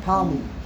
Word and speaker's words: Ptolemy: Ptolemy.